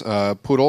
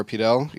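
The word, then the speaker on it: PDAL: Poodle or Pidel, either pronunciation is fine, and Entwined.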